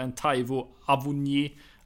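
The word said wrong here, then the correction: Awoniyi: Avoniji. – Avoniji, tack.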